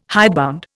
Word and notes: hidebound: Indian

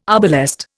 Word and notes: arbalest: Indian

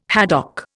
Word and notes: haddock: Indian

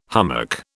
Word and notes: hummock: British English

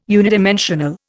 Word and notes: unidimensional: Indian

